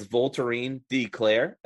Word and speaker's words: Voltairine de Cleyre: So it's Voltairine de Cleyre, and it's spelled like it.